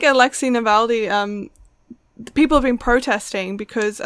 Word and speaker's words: Alexei Navalny: And also Alexei Navalny.